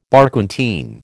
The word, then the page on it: Barquentine: American English